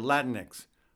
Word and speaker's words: Latinx: How do you pronounce A-L-A-L-A-T-I-N-X? I'm not even sure. Latinx. Poder Latinx.